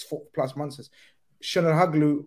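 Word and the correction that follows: Çalhanoğlu: how do you say his name, Michael? – Chanoglu.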